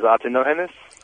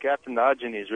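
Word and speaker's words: Atenógenes: It's actually Greek, but pronounced in Spanish. – What sense does that make? – Huh? – What sense does that make? How do you pronounce it in Greek? – It's more like Atenogenes, really.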